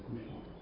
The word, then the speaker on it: couchant: Remember, a lot of French words that come into English are given more or less French pronunciations. So I think my number is couchant.